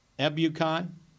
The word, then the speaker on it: Ebukam: The Ebukam, is that how you say it? – Yep.